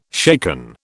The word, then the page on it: shaken: British English